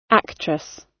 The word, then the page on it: actress: British English